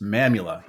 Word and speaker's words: Mamula: I'm chatting with Chris Mamula.